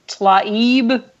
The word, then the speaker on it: Tlaib: Oh, that Tlaib. T-L-A-I-B.